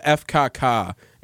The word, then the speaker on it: FKK: It's called FKK. That's how you say it in German. – Easy. Where's the FCC? – That's how it's said in German.